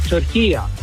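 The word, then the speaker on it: Türkiye: As far as the pronunciation of turkey, it's very easy. At the end is a short A. It's not a long A. In the Arabic language, it's called turkiya. So you could say turkiya.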